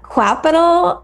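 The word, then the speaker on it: Qapital: How do you say that? Capital? Capital? It's capital.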